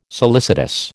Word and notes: solicitous: American English

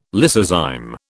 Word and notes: lysozyme: British English

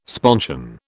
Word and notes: sponsion: British English

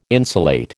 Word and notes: insulate: American English

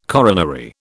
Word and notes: coronary: British English

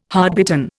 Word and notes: hardbitten: Indian

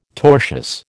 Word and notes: tortious: American English

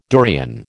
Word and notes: durian: American English

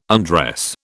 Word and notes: undress: British English

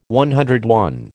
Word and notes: CI: American English

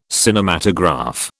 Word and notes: Cinematograph: British English

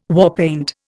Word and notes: warpaint: Indian

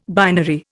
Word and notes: binary: Indian

